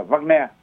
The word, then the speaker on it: Wagner: Nên là đúng là nếu đọc theo tiếng Đức thì người ta phải đọc là Wagner.